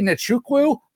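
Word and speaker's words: Nzechukwu: Going up against, I'm going to give it a try now, Kennedy Nichukwu. I think that's right. Dude, 7-1. – Good job. – Golf clap. – Hey, I'm all about Silent Z's.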